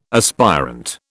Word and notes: aspirant: British English

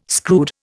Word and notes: screwed: Indian

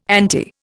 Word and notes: anti: Indian

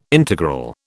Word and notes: integral: British English

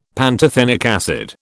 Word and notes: pantothenic acid: British English

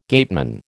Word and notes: gateman: American English

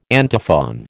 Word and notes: antiphon: American English

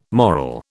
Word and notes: moral: British English

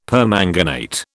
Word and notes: permanganate: British English

